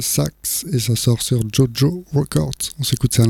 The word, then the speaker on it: Tjotjo: On va continuer toujours dans les nouveautés, cette fois-ci avec Jojo Records. Ça, je le dis comme ça parce que ça s'écrit T-J-O-T-J-O, Jojo Records, Big Word for My Little Bird, et donc c'est de Netherlands.